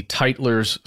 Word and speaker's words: Tytler: you need to know that it's spelled TYTLER'S cycle.